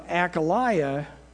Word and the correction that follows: Ahaziah: I'm not saying that right. Hold on. That's one of those biblical names. It's like, what was that again? Okay, Ahaziah. There we go.